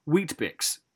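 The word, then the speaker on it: Weet-Bix: They have Wheatbix. – Wheatbix? – Yeah, they don't have Weetabix. It's Wheatbix. – Wheatbix, mate. – Maybe it's just too hot for that extra syllable.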